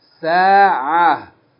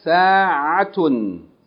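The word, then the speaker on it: ساعة: If we stopped on it, we would say سَاعَةٌ. Like ha, ha, sa'ah. But if we keep reading, we say sa'atun.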